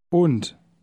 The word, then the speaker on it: Und: Notice in word 1 that the D at the end is pronounced like T. Say word 1 again. – Und.